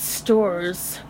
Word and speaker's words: Stoors: How would you pronounce that? Stors? – I think it's Stors. S-T-O-O-R-S.